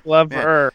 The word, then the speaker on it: Glover: Like a glove. – All right, sorry. – But it's more like it's spelled. More glove. It's Glover. Man, I got to say you guys have become very, very picky with pronunciation.